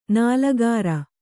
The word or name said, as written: ನಾಲಿಕೇರ